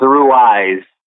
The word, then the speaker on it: Ruiz: I believe it's Ruiz. I had to check myself. I'm with you. I'm pretty certain it's Ruiz.